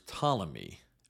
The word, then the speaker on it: Ptolemy: One of his generals was Ptolemy. And by the way, that's spelled with a silent P, P-T-O-L-E-M-Y.